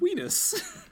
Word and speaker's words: Unas: It's not, spelled it's spelled U N A S or U N I S, but it's pronounced Weenus.